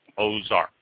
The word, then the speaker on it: Aux Arc: So, Chateau Ozark, and you notice I pause every time I say that name because they spell it A-U-X-A-R-C. Ozark. A-U-O-X-A-R-C. Ozark. But they separate A-U-X, separate A-R-C. And then they have a little thing pronounced Ozark.